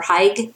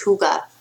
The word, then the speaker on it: Hygge: Huga, it's spelled H-Y-G-G-E. So it looks like Hygge or Haig, but it's actually pronounced Huga.